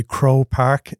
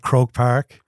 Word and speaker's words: Croke Park: So I would say Crow Park instead of Croke Park.